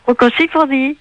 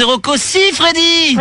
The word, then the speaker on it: Rocco Siffredi: Rocco Siffredi. – Eh non, c'est Rocco Siffredi. – Freddy, oh ben j'ai mal prononcé.